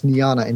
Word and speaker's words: Niejna: So we went for the next best thing, N-I-E-J-N-A, which is how you pronounce Niana in Flemish.